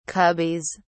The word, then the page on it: cubbies: British English